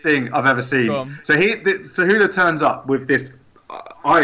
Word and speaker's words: Cejudo: So anyway, Cejudo? – Cejudo, yeah, Henry Cejudo.